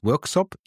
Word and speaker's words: Worksop: Also, lieber Philipp, wir sind im Jahr 1958 und ich übergebe sogar direkt an den Daniel, denn er darf ein bisschen erzählen, ein kleines Örtchen namens Worksop in Nottinghamshire, so spricht man es aus.